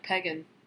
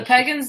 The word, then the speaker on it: pagan: I was like, or when people pronounce pagan like pegan. That drives me nuts, too. Oh, the pagans, the biker gang? And I'm like, no. – No, it's pagan and no.